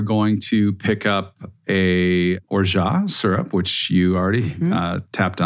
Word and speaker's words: orgeat: Orgeat is spelled O-R-G-E-A-T. So it doesn't look like orgeat, but it sounds like orgeat.